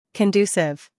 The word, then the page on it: conducive: American English